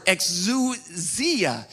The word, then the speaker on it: exousia: And in the Greek, it is actually the word exousia. Exousia is how it's pronounced.